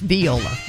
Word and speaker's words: viola: Well, then Tulsa Todd texted us back and said it's pronounced viola, not viola. But we're from the South, so maybe it's a Southern thing.